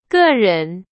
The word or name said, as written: 个人